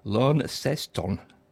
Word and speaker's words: Launceston: We're listening in a place called pronounced Launceston, right, apparently,